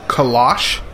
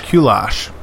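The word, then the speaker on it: Kölsch: Kolosh style ale. It's K-O with an umlaut. – Umlaut. – L... S-C-H. – Kulosh.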